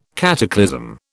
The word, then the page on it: cataclysm: British English